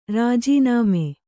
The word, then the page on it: ರಾಜೀನಾಮೆ: rājī nāme